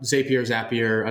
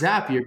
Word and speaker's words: Zapier: So I always think it's Zapier because it rhymes, but I couldn't tell you.